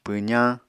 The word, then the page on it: pwzañ: Apatani